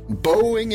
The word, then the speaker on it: Boeing: Boeing är det mycket riktigt. Eller Boeing som man kan uttala det.